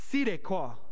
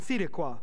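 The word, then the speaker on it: tzedekah (צדקה): And the word is, to help you pronounce it, tzedekah. I can't say it as well as I'm sure somebody who speaks Hebrew could say it, but the Z has an S sound, tzedekah. In Hebrew, okay, let me go ahead.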